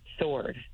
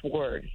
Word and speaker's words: sword: Sword. Sword. He said sword with a W, and I said sword.